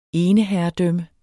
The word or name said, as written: eneherredømme